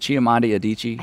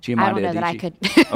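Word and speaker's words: Chimamanda Adichie: I hope I'm saying her name correctly. Is it Chiamanda Adichie? – I think so. – Chiamanda Adichie.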